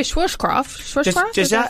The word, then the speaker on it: Schwarzkopf: Yeah, it was only a Schwarzkopf. That's how you say it.